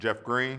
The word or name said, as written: Jeff Green